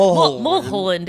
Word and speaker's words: Mulholland: Like swallowing the end of the words. Like Mulholland is Mulholland. The right way to say Mulholland.